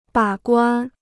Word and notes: 把关: bǎ guān

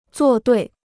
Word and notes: 作对: zuò duì

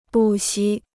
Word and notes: 不惜: bù xī

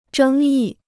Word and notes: 争议: zhēng yì

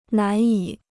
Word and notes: 难以: nán yǐ

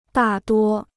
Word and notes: 大多: dà duō

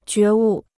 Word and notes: 觉悟: jué wù

